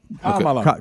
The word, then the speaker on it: Kamala: But they're saying now, again, I know we had to be schooled on how to pronounce her name correctly. Is it Kamala Harris? – Yeah. – Kamala. – Kamala. – It is. – Kamala. – Kamala. I got you. So it's Kamala Harris.